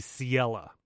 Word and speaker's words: Ciela: So he explains to us that our fairy friend, which I looked up the pronunciation of this, and I believe it's supposed to be Ciela. And that's supposed to be like sea.